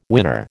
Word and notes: winner: American English

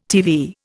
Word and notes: TV: Indian

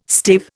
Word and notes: stave: Indian

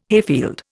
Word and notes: hayfield: Indian

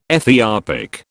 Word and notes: Ethiopic: British English

